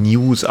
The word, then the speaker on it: NIUS: Das Medium heißt ja News, also N-I-U-S geschrieben, News ausgesprochen.